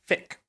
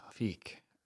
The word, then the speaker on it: fiqh: So my word for you is fick. – Cool. – And it is F-I-Q-H. – Okay, F-I-Q-H. – Yes. – Ooh, fick.